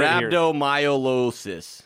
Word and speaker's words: rhabdomyolysis: I'm going to get rhabdomyolysis.